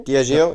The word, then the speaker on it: Diageo: Diageo, ja.